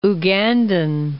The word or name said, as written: Ugandan